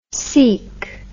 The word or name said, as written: seik